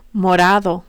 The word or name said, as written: morado